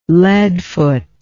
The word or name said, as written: leadfoot